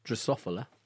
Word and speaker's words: Drosophila: it was just to say how useful, let me give you the right pronunciation, Drosophila is at studying neurological diseases, neurodegenerative diseases and other.